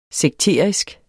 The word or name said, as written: sekterisk